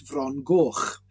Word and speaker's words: Frongoch: Because to me and to us, it's Frongoch, a completely different pronunciation.